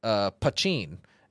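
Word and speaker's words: poitín: This is actually called, so it's a essentially Irish moonshine, but it's Pachin is the way it's pronounced, and it's a mountain strength.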